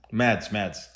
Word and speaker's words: Mads: Is it Mads or Mas? – Mads. I call him Mads. – Okay, good. Mads, okay. I'm going to call him Mads then. Yeah. I like Mads.